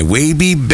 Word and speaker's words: Waby: It's W-A-B-Y. Wabby baby. That's why we say baby, so you know how to say it.